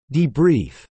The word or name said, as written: debrief